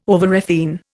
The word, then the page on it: overrefine: Indian